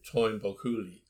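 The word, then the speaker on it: Táin Bó Cúailnge: Well, an accent over a letter in Irish, it's always over a vowel, is called a fadder, and it lengthens the vowel. – Yeah. – So Toyn is spelled T-A-F-A-D-D-I-N. – Yeah. – And... anyway, it's Toyn Bo Cooley.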